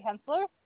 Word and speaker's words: Hensler: My name is Cindy Hensler.